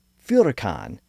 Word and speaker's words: furacan: Now, in actuality, it seems much more likely that the natives yelled "Furacan" with an F sound at the beginning.